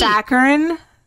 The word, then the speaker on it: saccharine: Saturine. It's like too sweet. – Saccharine. Yeah. – Thanks. – There's no T in saccharine.